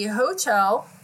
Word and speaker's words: Hochul: New York Governor Kathy Hochul, is that how you say her name? – Sure. – Okay. – I think it sounds great.